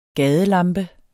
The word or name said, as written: gadelamper